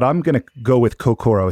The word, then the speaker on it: Kokoro: Yeah, we pronounce it Kokoro, but I think your pronunciation is technically more accurate. It's just we started calling it Kokoro. – Kokoro. The American Kokoro. Pronunciation, which is totally legitimate.